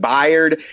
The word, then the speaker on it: Byard: However, they turned out a local kid into the pros this year, young safety Kevin Byard. I think I'm pronouncing his name correctly. Talk about that guy. – Well, it's Kevin Byard.